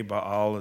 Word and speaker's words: Baal: Baal, some say. Baal is probably a more appropriate pronunciation.